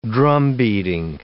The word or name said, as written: drumbeating